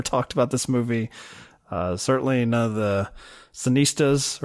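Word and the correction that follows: cineaste: I hate the fact that you're calling this back, because I actually mispronounced it. It's cineast.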